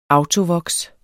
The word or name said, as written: autovoks